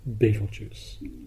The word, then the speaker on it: Betelgeuse: But not many people know it by that name because it has a spectacular common name, and that is Betelgeuse. Yes, that's right, there is a star called Betelgeuse. Some people pronounce it as Betelgeuse or Betelgeuse, but they're all mispronunciations of an old Arabic name, Yad al Yaza, meaning the hand of the big man, or as we now call it, Orion's brightest star, Betelgeuse.